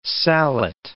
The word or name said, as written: sallet